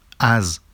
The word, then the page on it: az: Vafsi